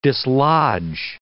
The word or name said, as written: dislodge